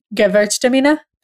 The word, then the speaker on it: Gewürztraminer: But my next order from Wink Wine is going to be, or is going to contain, Gewürztraminer. – That's how you say it? – Gewürztraminer.